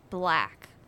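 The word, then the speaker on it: 6lack: I think there's this one rapper, and I think the way that you pronounce it is Black, but instead of a B, it's a six.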